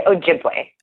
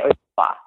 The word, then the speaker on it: Ojibwe: It's Ojibwa? Is that how you say it? – I say Ojibwe. – Ojibwe. – Some people say Ojibwa.